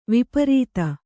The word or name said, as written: ವಿಪರೀತ